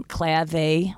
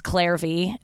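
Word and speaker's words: Clare V.: Really nice purse from Clave, which is... It's Claire V. It's like a nice, like, leather company. But in purses and stuff. But it's Australian, so I always pronounce it Clave because that's how they talk.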